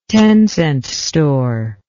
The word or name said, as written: ten-cent store